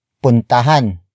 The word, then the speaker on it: puntahan: Another example, puntahan mo ang ospital. Puntahan mo ang ospital. Puntahan mo ang ospital. It means go to the hospital. The focus is on the hospital. Word for word it's puntahan mo ang ospital.